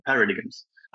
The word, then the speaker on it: paradigm: you're going to use the same paradigms. I don't even know if that's a word in English though, but yeah. – It is. Pronunciation is paradigm, but yes, paradigm. it's a word.